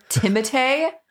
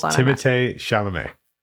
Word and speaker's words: Timothée: Timothée. Don't tell me that's how you pronounce it. – Yeah. – Wait, are you serious? – Yeah. – Timothée? – Uh-huh. He's French. It's Timothée Chalamet.